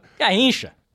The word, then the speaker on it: Garrincha: Garrincha. – All right, Garrincha.